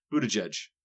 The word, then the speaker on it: Buttigieg: Must be a hard German name to pronounce. It's Buttigieg. Just Buttigieg.